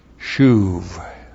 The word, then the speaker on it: shuv: Shuv. This is one of the words in Hebrew for the doctrine of repentance. S-H long U-B. Shuv.